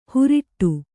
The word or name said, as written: ಹುರಿಟ್ಟು